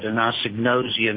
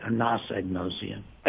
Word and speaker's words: anosognosia: Okay, I've been saying agnosia. I've been pronouncing it wrong, I guess. Uh-oh, I'm in trouble. – A-G-N-O-S, agnosia. – Intoxication agnosia. Yeah, I've been... – Anosognosia. – Agnosognosia, okay. – There you go.